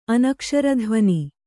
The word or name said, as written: ಅನಕ್ಷರಧ್ವನಿ